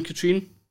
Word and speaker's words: Katrine: How are you doing, Katrine? – Hello, thank you for having me.